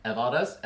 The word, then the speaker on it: Evaldas: Evaldas, your name.